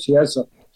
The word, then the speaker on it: Chiesa: one of them was Michael Chiesa. I said it right? Chiesa. – Yep.